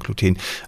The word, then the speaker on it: Gluten: Es gibt selbst unter Wissenschaftlern Leute, die sagen Gluten und die anderen sagen Gluten. Also ich glaube, da gibt es keine korrekte Weise, das auszusprechen, aber die häufigste ist schon Gluten. Im Englischen spricht man es, glaube ich, eher so aus, wie wir jetzt im Deutschen die zweite Variante haben, ne? Also Gluten, im Deutschen kenne ich es überwiegend als Gluten.